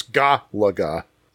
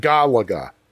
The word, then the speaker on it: Galaga: That's not what he says. He says, which I pronounce Galaga. Emphasis on the Galaga. – First Galaga.